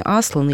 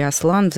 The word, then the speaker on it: Аслан: Я думаю, что многие читали, но кто не читал, я поясню, что дети попадают в волшебную страну, в которой правит лев по имени Аслан или Аслан, в зависимости от того, когда переводилась эта книга, ударение может падать на первый или на второй слог в этом имени.